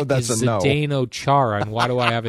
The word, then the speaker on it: Zdeno Chara: For Washington Capitals star Zdeno Chara. Did I nail that? – Future Hall of Famer. – Did I say his name correctly? – Zdeno Chara, yeah. – Zdeno Chara. – Yes.